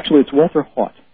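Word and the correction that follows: Haut: I got corrected. Hot. Everybody pronounces him incorrectly. It's Walter Hot.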